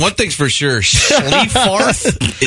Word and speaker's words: Schleiffarth: I love this name. S-C-H-L-E-I-F-F-A-R-T-H. Schlieffarth. – Yes, that's a silent H.